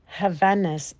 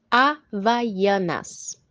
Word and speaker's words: Havaianas: Havanas. How do you say that again? – Havaianas.